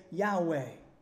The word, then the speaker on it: Yahweh: They've actually put vowels in, A and E, so that you can say Yahweh.